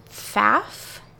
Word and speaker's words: Pfaff: And then there is Kristen Pfaff, I think is how you say it, P-F-A-F-F, who died of a heroin.